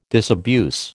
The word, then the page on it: disabuse: American English